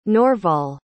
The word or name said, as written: norval